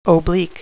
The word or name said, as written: oblique